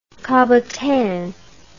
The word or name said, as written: cabotin